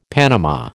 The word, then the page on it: panama: American English